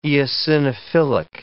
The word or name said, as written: eosinophilic